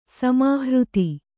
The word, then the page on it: ಸಮಾಹೃತಿ: samāhřti